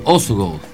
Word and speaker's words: Осогово: Прекрасен футболен празник на стадион Осогово в Кюстендил. – Ама ударението Осогово. – Значи, уважаем шадри, за да не се засегнете нещо, питали сме и сме проучили. Това е ударението. Това е правилното ударение.